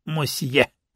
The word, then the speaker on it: мосье: — Добрейшего вам вечерочка, мадемуазели, мосье! – В Одессе принято обращение мадам, мадемуазель и мосье. Последнее слово произносится именно с четко артикулируемым звуком «О».